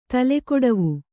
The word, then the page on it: ತಲೆಕೊಡವು: tale koḍavu